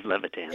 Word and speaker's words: Levitan: Is it Levitan? Is that how you pronounce it? – Yeah, Steve Levitan.